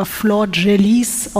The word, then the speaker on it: Flordelis: Ich weiß, es wird auch vielleicht ein bisschen komisch, weil der Name wird anders geschrieben, als er gesprochen wird. Also er wird Flor Delis oder Flor Delis geschrieben, aber Flor Gelis ausgesprochen.